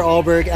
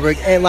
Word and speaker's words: Alberg: It's Andrew underscore Alberg, at Andrew underscore Alberg. Last name is spelled A-L-B-E-R-G.